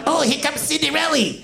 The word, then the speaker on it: Cinderella: Cinderella. – All right. You know, in the first round, it's cute to mispronounce things, but... – Right, sorry. Cinderella.